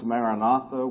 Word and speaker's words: Maranatha: It's an Aramaic word, but it's the word we pronounce Maranatha, which means it's two words.